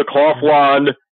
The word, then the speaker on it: Kaufland: There is a version of Walmart called Kaufland, K-A-U-F-L-A-N-D, pronounced Kaufland.